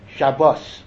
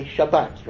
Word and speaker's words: Shabbat: So we say Shabbos. But even in Mea Shearim, right, when a chassid speaks Hebrew, modern Hebrew, he'll always say Shabbat, right? He'll say it that way.